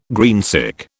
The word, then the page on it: greensick: British English